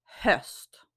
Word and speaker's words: höst: Fall in Swedish is höst.